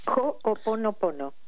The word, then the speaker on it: Ho'oponopono: And she's a leading authority, and I hope I pronounced this correctly, so please correct me if I mispronounce it, Mabel, but it's the Ho'oponopono, is that right? An ancient Hawaiian art and practice? – Ho'oponopono. – Good. Okay, good.